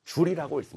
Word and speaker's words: 줄: 단위는 J라 쓰고요. 읽을 때는 줄이라고 읽습니다.